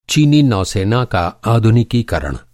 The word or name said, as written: आधुनिकीकरण